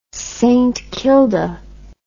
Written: saintkilda

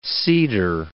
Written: seeder

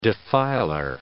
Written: defiler

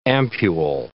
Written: ampule

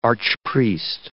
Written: archpriest

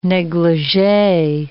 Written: negligee

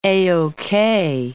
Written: A-OK

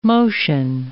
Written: motion

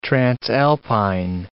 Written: transalpine

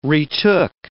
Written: retook

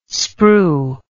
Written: sprue

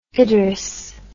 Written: idris

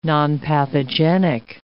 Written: nonpathogenic

